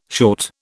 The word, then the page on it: short: British English